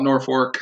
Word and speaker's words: Norfolk: Appalachian State versus Norfolk State. If you're from Nebraska, you call it Norfolk. I'm pretty sure you pronounce it Norfolk State for this one.